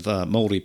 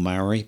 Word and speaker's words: Māori: There's an indigenous population of Māori people. You've probably heard them called Maori, but the correct pronunciation nowadays is called Māori.